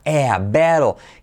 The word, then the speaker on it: battle: En este caso, se escribe con una A, ¿vale? Y se pronuncia como ea, battle.